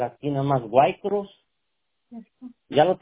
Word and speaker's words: Waycross: Ok, se escribe WAYCROSS, White Cross, así se escribe.